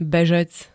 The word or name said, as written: bežec